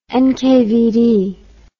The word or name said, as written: nkvd